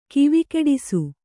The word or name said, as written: ಕಿವಿಕೆಡಿಸು